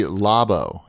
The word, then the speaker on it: Labo: Actually, it's probably Labo, if it's you can use the Japanese pronunciation, which I think we should.